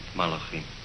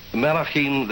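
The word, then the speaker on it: Malachim (מלאכים): From the ancient Hebrew text, we have the words B'nai Elohim, the sons of God, Kadoshim, the holy ones, and how do you pronounce that? – Malachim. – Thank you, Rabbi. Malachim, the messengers.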